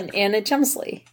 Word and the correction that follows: Chlumsky: Yeah. – Anna Chlumsky? – I guess I pronounced her name wrong. – Yeah. Anna Chlumsky.